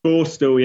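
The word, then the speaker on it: Borstal: Borstel, yeah.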